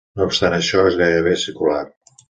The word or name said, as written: circular